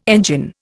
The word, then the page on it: engine: Indian